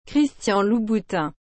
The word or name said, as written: Christian Louboutin